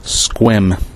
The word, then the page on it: Sequim: ˈskwɪm; SKWIM; American English